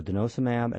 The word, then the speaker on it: denosumab: How do I say that one? Denosumab? – Oh, denosumab, yes. Actually, even more interesting is we've got some ongoing trials for systemic lupus, which we're very excited about.